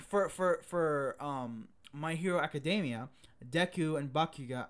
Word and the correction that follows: Bakugo: I can never say his name right. What was it? – Bakugo? – Like from them, Kachan?